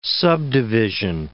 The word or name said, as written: subdivision